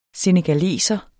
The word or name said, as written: senegaleser